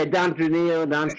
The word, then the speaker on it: Dontraneil: Yeah, DONTRANEIL.